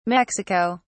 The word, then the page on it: mexico: British English